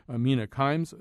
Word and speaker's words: Mina Kimes: But joining us to talk about all this is Mina Kimes, senior writer and columnist at ESPN the magazine. First of all, welcome. And second of all, am I saying your name correctly? – You said it perfectly.